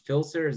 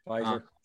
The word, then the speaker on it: Pfizer: So at least the Filser, is that how you pronounce it? The main one? – Pfizer.